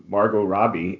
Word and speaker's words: Margot Robbie: I think it's really, as you guys pointed out, a testament to Margot Robbie. And I said her name right correctly, because Steve said it first, which prevented me from saying it incorrectly.